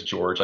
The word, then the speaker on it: Jorj: So the main character's name is George, like I said, and it's spelled J-O-R-J, sort of like a Nordic Viking spelling of the name George.